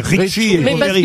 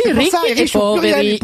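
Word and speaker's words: Ricchi e Poveri: Mais non, mais Ricky et Poveri, c'est riche et pauvre. – Mais oui, Ricky et Poveri. Et si, à l'accent tonique, Poveri.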